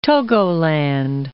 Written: Togoland

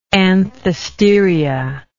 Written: anthesteriac

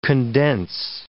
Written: condense

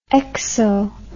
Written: exo

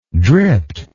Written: dript

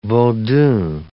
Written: vodoun

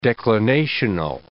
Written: declinational

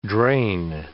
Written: drain

